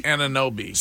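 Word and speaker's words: Anunoby: Ananobi.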